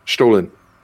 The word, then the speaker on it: Stollen: You didn't say it mean enough. – Stalin. It's Stalin. Spell it. How's it spelled? – Stollen. – Stolen. – Oh, okay. – Stolen. I would have known it if you had pronounced it right.